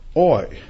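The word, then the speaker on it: oi (οὐαί): The first word is woe, which is pronounced oi.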